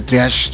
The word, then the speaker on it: triage: Se escribe triaje, ya me dijeron, no, se escribe triaje, pues se dice triage, ¿no?